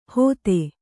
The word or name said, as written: ಹೋತೆ